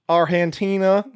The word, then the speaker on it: Argentina: With no accent whatsoever, they just changed the G to an H? – Yep. Ask them. – Argentina.